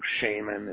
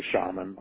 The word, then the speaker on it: shaman: Are you purposely saying shaman instead of shaman on purpose? So it has the word shame in it. Is that what you're doing? – A shaman. That is an alternate pronunciation of shaman, yes.